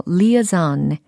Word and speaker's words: liaison: 그 다음엔 This is a dangerous 다음에 L-I-A-I-S-O-N 이라는 단어가 들렸어요. 발음은요, liaison, liaison 이와 같이 발음을 합니다.